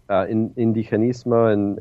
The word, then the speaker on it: indigenismo: There's a number of Haitianists who've written about, you know, the ways in which indigenismo in Haiti, and I'm using a Spanish pronunciation, but the Haitian responses to the occupation.